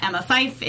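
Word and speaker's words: Emma Fyffe: It's spelled EMMAFYFFE. That is all F's as in Fred. No S's as in snake. If you're listening, F and S tend to sound the same.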